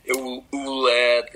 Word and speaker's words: Ouellette: And it's hard to pronounce, and it's super kind of French, the Ouellette, and it's Ouellette, and you know, like right. they misspell it, and sometimes T, no E before the double L, or just one L, two T.